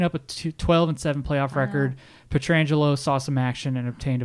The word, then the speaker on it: Pietrangelo: It's spelt like Pietrangelo. – Well, they are related. I think they're like second cousins, if I'm not mistaken. – And I've heard Frank Pietrangelo being announced or being pronounced Pietrangelo. So I'll just say Frank Pietrangelo.